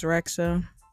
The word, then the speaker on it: Rexha: I think it's Rexha.